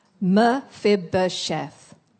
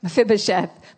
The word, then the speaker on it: Mephibosheth: He's got a very long name, Mephibosheth. Can you say that? Mephibosheth. 1, 2, 3, 4, 5 syllables. Mephibosheth. 4, sorry. Mephibosheth.